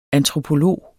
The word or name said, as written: antropolog